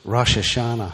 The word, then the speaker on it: Rosh Hashanah: As Jeremy and Stacy join together, and as today is Rosh Hashanah.